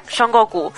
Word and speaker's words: Son Goku: Y la novela explica la vida del rey mono, que es nuestro protagonista, que en chino se llama son pero si la adaptamos a la fonética japonesa se vuelve mucho más sospechoso porque se pronuncia Son Goku.